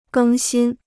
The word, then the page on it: 更新: gēng xīn